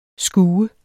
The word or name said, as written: skue